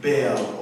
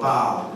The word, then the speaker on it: Baal: They were actually following after a Canaanite god named Baal or Baal, depends on how you want to pronounce it.